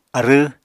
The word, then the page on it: arww: Apatani